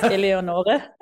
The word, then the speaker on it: Eleonore: Almost. It's Eleonore. – Oh, damn. Okay, Eleonore. It's Italian, right? – Yeah, actually, it is the German version of an Italian name.